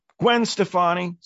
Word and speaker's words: Gwen Stefani: Gwen Stefani.